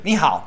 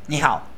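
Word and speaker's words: Ni hao (你好): Ni hao. – Sí, milhao está en los Simpsons. Sí. – No, en serio, boludo. Aprendí una palabra en chino, una. Solamente esa. Ni hao, que es hola.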